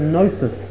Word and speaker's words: gnosis: That is G-N-O-S-T-I-C-S. Gnostic. Now the G is silent. It's a Greek word. It comes from the Greek word gnosis. G-N-O-S-I-S.